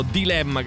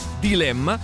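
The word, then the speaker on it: Dlemma: Loro si chiamano Dilemma, che si scrive con la D e poi Lemma, quindi praticamente Dilemma senza la I, Dilemma.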